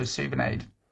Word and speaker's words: Souvenaid: So it's SOUVENAID. So Suvenaid.